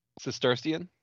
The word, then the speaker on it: Cistercian: It may even be Cistercian with an SH in there, but either way.